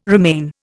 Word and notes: remain: Indian

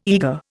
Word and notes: eagre: Indian

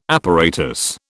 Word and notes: apparatus: British English

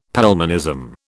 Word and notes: Pelmanism: British English

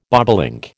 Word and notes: bobolink: American English

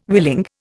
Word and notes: willing: Indian

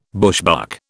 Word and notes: bushbuck: British English

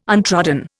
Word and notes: untrodden: Indian

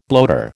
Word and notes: bloater: American English

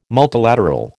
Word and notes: multilateral: American English

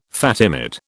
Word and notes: Fatimid: British English

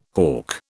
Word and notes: hough: British English

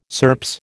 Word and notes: SERPS: American English

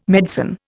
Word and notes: medicine: Indian

